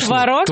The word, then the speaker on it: творог: Что, теперь все обсуждают ударение сыр и творог? – Конечно, творог и творог. И тебя тут, надо сказать, ты не выигрываешь совсем. – Ну, потому что говорят, что оба варианта можно творог и творог.